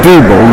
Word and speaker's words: Diebel: My name is Diebel. That's spelled D-I-E-B-E-L.